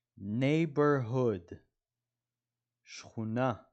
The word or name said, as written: neighborhood